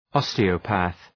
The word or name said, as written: osteopath